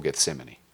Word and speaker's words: Gethsemane: The moment he performs the first miracle, he sets the clock for Gethsemane.